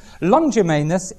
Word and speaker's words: Longimanus: A-R-T-A-X-E-R-X-E-S, Artaxerxes Longimanus, L-O-N-G, I-M-A-N-U-S.